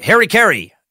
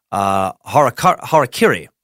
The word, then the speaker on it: harakiri: I've always heard it as like Harry Carey. That's definitely wrong. That is definitely wrong if you're trying to pronounce it right. Harakiri.